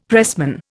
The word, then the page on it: pressman: Indian